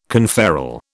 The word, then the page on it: conferral: British English